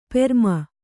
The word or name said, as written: ಪ್ರೇಮಿ